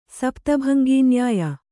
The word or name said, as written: ಸಪ್ತಭಂಗೀನ್ಯಾಯ